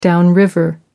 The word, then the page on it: downriver: American English